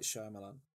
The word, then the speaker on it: Shyamalan: M Night Shyamalan.